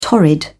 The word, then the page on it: torrid: British English